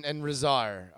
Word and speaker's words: Rezar: Rezar? – Akam and Rezar are their names, yeah.